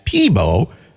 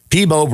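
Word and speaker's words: Peabo: Freeman's frequent mispronunciation of Bryson's French West Indian name, Peepo, as Peebo, led the singer to adopt the mispronunciation as his stage name.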